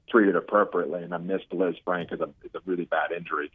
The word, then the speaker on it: Lisfranc: But he's Taysom's dealing with a Liz Frank injury, and it's spelled L-I-S-F-R-A-N-C, not like a woman named Liz Frank.